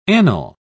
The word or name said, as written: Anil